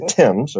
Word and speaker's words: Thames: You spell it T-H-A-M-E-S, river. – Okay, so it's the Thames. – You say it's the Thames, okay. Yep. What do you say? – The Thames. I grew up in London, Ontario, which is obviously named after London, England.